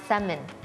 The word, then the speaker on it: salmon: salmon เราจะออกเสียงว่า salmon แค่นั้นนะคะแล้วตัว L เหมือนอันตรธานหายไปไม่มีใครได้พบเจอนะ